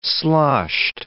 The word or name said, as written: sloshed